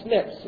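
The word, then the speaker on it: SNP: Okay, also what are called SNPs is how it's pronounced.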